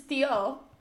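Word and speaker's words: Tió: This is Tio, so the accent is on the O, not the I.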